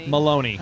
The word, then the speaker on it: Maloney: Filoni? – Oh, yeah. Maloney. – Maloney. – Rhymes with baloney.